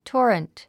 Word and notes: torrent: tɔ́ːrənt; トォレント

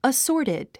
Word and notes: assorted: əsɔ́ːrtid; アッソォテド

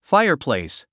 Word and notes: fireplace: fáiə r plèis; ファイアプレイス

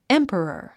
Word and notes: emperor: émpərər; エンペラァ

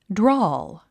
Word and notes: drawl: drɔ’ːl; ドローゥ

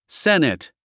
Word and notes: senate: sénət; セネト

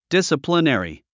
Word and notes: disciplinary: dísəplinèri

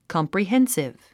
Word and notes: comprehensive: kɑ`mprihénsiv; コンプリヘンシブ

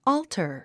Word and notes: altar: ɔ́ːltər; オールタァ

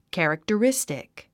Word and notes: characteristic: kæ`riktərístik; キャラクタリスティック

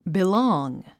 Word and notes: belong: bilɔ’ːŋ; ビローング

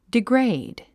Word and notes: degrade: diɡréid; ディグレイド

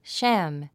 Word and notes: sham: ʃǽm; シャァム

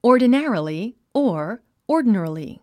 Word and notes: ordinarily: ɔ̀ː r d ə né ə rəli; オゥディナラリィ

